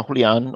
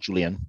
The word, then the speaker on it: Julian: Sí, sí, se pronuncia Julian o también como mi nombre normal, Julian. Está bien.